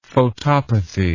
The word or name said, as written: photopathy